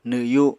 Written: nwyu

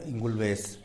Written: ingulbes

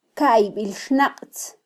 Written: kaybilxnaqtz

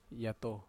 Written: ato

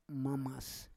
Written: mamas

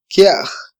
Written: kyaj

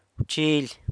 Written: bchily

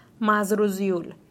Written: masruzuill

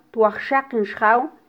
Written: twajxaqin xjaw

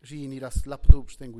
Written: xiniraslakdubxtenga

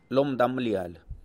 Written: lomdambialy

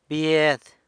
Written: bied

